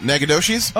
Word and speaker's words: Nacogdoches: Oh, is it Nacogdoches? – Oh, there you go. – Is it New Mexico? – Texas, it says. – Texas? – It might be. – Yeah, I got it. – Nacogdoches.